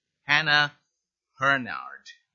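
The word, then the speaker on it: Hannah Hurnard: I started reading a very good book entitled Hinds Feet on High Places by Hannah Hernard. I'm sure I say that name wrong, but Bill is nodding, so that's correct.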